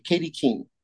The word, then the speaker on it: Katie Keene: Kathy Keene. Am I pronouncing that one correctly? – Katie Keene. – Katie Keene. Yep.